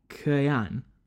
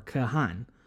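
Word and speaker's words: Khagan: Whereas the second title, Kagan, or if we're really trying to pronounce it totally correctly, Kayan or Kahan, means essentially Khan of Khans, or Great Khan, the overall ruler of a Khaganate.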